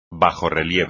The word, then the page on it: bajorrelieve: espagnol